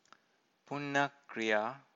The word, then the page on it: punna-kriya: Pali